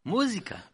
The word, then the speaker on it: Musica: How do you pronounce, how would you say it? – Musica.